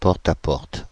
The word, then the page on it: porte-à-porte: /pɔʁ.t‿a.pɔʁt/; France (Île-de-France); Paris